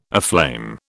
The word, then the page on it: aflame: British English